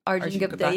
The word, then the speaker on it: Arjun Gupta: She also wants to know, and I please correct my pronunciation here, what it's like working with Arjun Gupta. Did I say it right? – Arjun Gupta. – Yeah. That's what I thought.